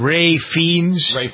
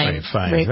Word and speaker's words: Ralph Fiennes: Ralph, how do you pronounce it? Ray Fiennes? – Ralph Fiennes.